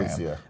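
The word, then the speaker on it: Alessia: Alyssa Cara won Best New Artist. Am I saying her name right? I think Alicia.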